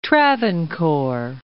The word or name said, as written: Travancore